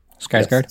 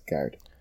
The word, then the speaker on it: Skarsgård: And and all the kind of like, even the stuff with like Stargard, what's it, is that how you pronounce his name? – Skarsgård. – Yes, Skarsgård.